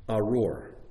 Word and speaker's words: arur: But the word that's used when they speak to the people on Mount Gerizim and Mount Ebal is the word arur.